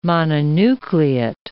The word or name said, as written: mononucleate